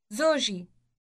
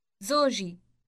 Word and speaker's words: زوجي: زوجي. زوجي. – Note that my is represented by an E sound added to the end of the word. Say my husband again. – زوجي.